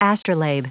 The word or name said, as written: astrolabe